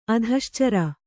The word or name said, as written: ಅಧಶ್ಚರ